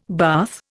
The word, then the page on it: bath: Indian